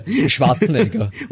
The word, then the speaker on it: Schwarzenegger: And I mean, the pronunciation for Schwarzenegger. So how do you say it? I mean, you've said it twice already, but I want you to... – Schwarzenegger. – What's... – Schwarzenegger would be Arnold Schwarzenegger. – Okay, cool. There you go. That's the official Austrian pronunciation of Arnold Schwarzenegger.